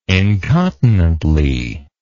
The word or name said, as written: incontinently